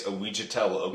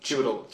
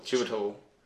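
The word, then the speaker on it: Chiwetel: I'm going to say it's Ouijitel Okafor. – No, it starts with a CH. It's Chitwell. Chitwell. Chitel. Is it Chewittle? – Like... Chewittle. Chewittle.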